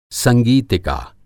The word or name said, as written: संगीतिका